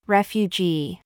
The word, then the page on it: YOU: American English